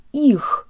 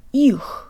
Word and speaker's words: их: Let's say this word with good Russian default mouth position. Jaw slightly forward, tip of the tongue behind the bottom teeth, lips rounded and protruded. Ich.